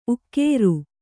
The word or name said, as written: ಉಗುರ್